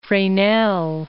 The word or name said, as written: Fresnel